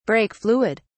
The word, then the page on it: brake fluid: British English